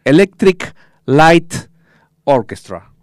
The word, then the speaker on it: Electric Light Orchestra: Y previamente estamos hablando de Electric Like Ah, perdón, perdón, perdón, lo pronuncié mal. Electric Light Orchestra.